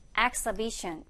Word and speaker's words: exhibition: Exhibition. 얘가 이제 스펠링과 발음이 조금 차이가 있어요. 이렇게 생겼지만 읽을 때는 어떻게? Exhibition.